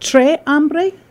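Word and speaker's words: Tres Hombres: Is it Tres Hombre? Do you pronounce the S? T-R-E-S? – I would say with the S.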